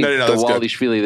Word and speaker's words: Dvalishvili: D-V-A-L-I-S-H-V-I-L-I. – Got it. – Yeah. Well, because I'm saying Dawalishvili, there's no W in there, but that's how you pronounce his name.